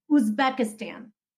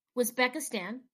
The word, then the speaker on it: Uzbekistan: How do we say it? – Uzbekistan. – Uzbekistan. – Yes.